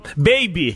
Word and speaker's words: Babi: É e, eu, sabia a pronúncia desse jogador. Lembra? Nós falamos, baby. Pois. – Você, você que falou que é baby, eu falei, não é babi, você falou, não é baby. É Matheus Baby, a revelação.